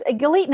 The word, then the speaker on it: Galit: So, Nate, my name is the best to remember that it rhymes with the word elite. So, Galit Elite.